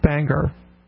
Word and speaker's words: Bangor: Hey, I've got to give both of you props for correct pronunciation of Bangor.